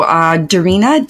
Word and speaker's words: Darina: So Darina, D-A-R-I-N-A, Kopchok is spelled K-O-P-C-O-K.